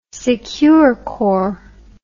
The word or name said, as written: Securicor